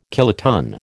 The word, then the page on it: kiloton: American English